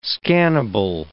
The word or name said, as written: scannable